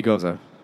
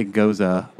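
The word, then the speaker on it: Gose: Any language other than English, it would be goza. – So you can do both pronunciations, but I say goza, which seems to be the more popular.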